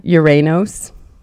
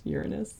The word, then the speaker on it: Uranos: He's Uranos. I'm saying it the Greek way.